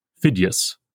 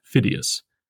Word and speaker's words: Phidias: In line 8, Phidias, not Phidias.